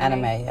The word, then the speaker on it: anime: Anime? – Anime, yeah.